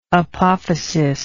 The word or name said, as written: apophysary